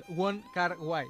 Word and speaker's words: Wong Kar-wai: Wong Kar-wai, és en tres paraules, i jo crec que no hi ha gens problemes per trobar-lo ja a la majoria de botigues.